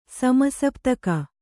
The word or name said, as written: ಸಮಸಪ್ತಕ